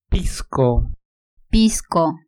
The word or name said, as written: pisco